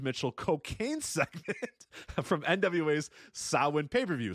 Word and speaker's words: Samhain: How did you actually pronounce this thing? – Sawin. – Sawin. – Sawin. – You went Sawin with Christopher Walken. I think it's Sawin.